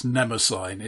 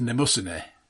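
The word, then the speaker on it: Mnemosyne: Well, I would have stopped them pronouncing it Nemosyne. It's Nemosyne.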